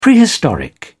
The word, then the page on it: prehistoric: British English